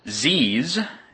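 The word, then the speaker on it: Pzizz: Going to look at a program called Ziz, as it's pronounced. It's P-Z-I-Z-Z,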